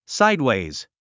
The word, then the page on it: sideways: sáidwèiz; サイドウェイズ